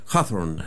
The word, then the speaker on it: Hawthorne: Tú vas a pronunciar mejor el apellido que yo. Nathaniel Hawthorne, ¿no? – Sí, creo que es Hawthorne. – Hawthorne, ya.